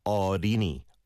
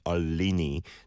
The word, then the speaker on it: 어린이: But the 어린이, it's 어린이, 어린이. Rather than Olini, don't say it with an overpronounced L sound. Leal is slightly lighter, I would say.